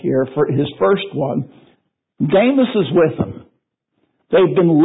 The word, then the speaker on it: Demas: And you might say Demas, but the Greek letter that's translated by the English E is an eta, so it has a long A sound. Demas.